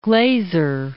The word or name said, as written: Glaser